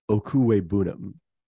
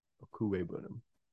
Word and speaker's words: Okwuegbunam: Dillon, Ramondre, Michael Gallup, Khalil Herbert, Trevor Lawrence, Spiller, and Ogunbanam. – To clean that up, Okwuegbunam is how to pronounce that. Okwuegbunam.